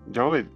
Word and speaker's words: Djokovic: Djokovic, ¿cómo se pronuncia? Tú se sabías a saber. – Djokovic. – Djokovic.